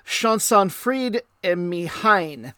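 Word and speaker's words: Llansantffraid-ym-Mechain: and I've been working on the pronunciation for this, Llansanfraid Y Mihain. And the letters look nothing like that to me because it's Welsh, but that is a small Welsh town.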